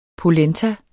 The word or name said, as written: polenta